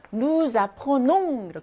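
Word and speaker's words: nous apportons: 누라고 하는 거는 원래 누스라고 발음하지 않아요. 원래는 누예요. 그런데 뒤에 나오는 아포농이라고 하는 첫 번째 모음으로 영향을 받아서 누자포농 이렇게 되는 겁니다.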